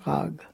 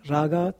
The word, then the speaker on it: raga: In Hindi you write raga, but you do not pronounce the vowel, so you say rag. It's the classical pronunciation, raga, tala, but in the Umgangssprache it's rag and tal.